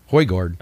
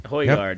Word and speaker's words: Højgaard: And I'm going to say, hopefully I say this wrong, Nikolai Højgaard. – Yeah, Højgaard. – Højgaard.